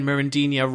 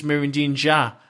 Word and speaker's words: Mirandinha: But I actually found out that we've been saying Mirandinha wrong for basically the last 30 odd years. Apparently it's Mirandinha. It's like a ja at the end of the name. Basically we've all said it wrong.